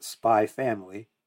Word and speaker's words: Spy x Family: So, we have this manga that came out a while ago from Tatsuya Endo called Spy X Family, which is really just pronounced Spy Family.